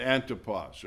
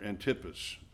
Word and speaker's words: Antipas: And it's likely the Lord here mentioned Antipas or Antipas, depending on where you want the accent on which syllable.